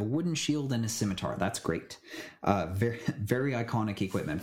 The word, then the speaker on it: scimitar: And then I choose scimitar or did I say that right? – Yes.